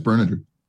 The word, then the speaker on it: Berninger: How do you pronounce his last name? – I think it's Berninger.